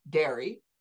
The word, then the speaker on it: D'Eri: Today, our guest, we have two guests today, Andrew and Tom Derry. The French way is it pronounced.